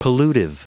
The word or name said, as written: pollutive